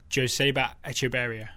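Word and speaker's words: Joseba Etxeberria: My number four is Athletic Bilbao's Joseba Echeverria, which I've possibly pronounced incorrectly. – Mm-hmm. You did good. Sounded nice.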